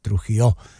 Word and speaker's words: Trujillo: Takže kromě Jamese, Lars Ulrich, známé to jméno, že ano, Kirk Hammett a Robert Trujillo, nebo Trujillo, chcete-li, když myslím, že se to vyslovuje ty dvě L jako J, Trujillo.